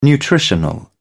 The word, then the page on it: nutritional: British English